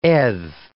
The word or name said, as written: edh